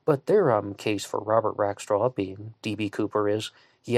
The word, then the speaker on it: Rackstraw: And I have heard at least one person online pronounce his name Robert Rakestraw, but everyone else has been going with Rackstraw, so I'm going to go with that one as well.